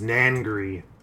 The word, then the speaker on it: Nangri: My name is Nangri. – Nangri? – Nangri. – N-A-N-G-R-I.